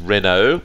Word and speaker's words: Renault: Yeah, just like when you pronounce Renault. – You obviously don't have much continental sophistication in you, so it is Renault.